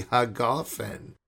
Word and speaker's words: hagafen: and he finishes Bore Pri Hajefin. Now I butchered it. If any Yemenite is out there, please, I apologize. Please forgive me. And I'm thinking, what is going on over here? I said, oh, that's Bore Pri Hagafen. Same words that I say. Same words that we Ashkenazi say. Printed identically in the prayer book. But he brought his mojo, his tradition from Yemen, 2,500-year-old tradition about how to pronounce the gimel, the jimel, as they would say.